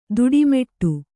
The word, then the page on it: ತಿಟ್ಟಮಿಡು: tiṭṭamiḍu